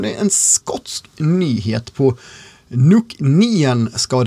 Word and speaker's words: Nc'nean: Alltså N-C-N-E-A-N. NC9, men nej, Nien ska det uttalas.